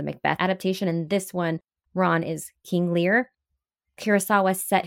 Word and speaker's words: Ran: There's also Akira Kurosawa's 1985, I believe it would be pronounced Ran because it's Japanese, so R-A-N, and Ran means mm-hmm.